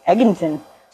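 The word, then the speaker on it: Egginton: Book written about her, and it's called From Cradle to Grave by Joyce Eginton. It's E-G-G-I-N-T-O-N. Eginton. – Eginton. – I'm sure that that's not pronounced right.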